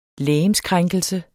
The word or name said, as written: legemskrænkelse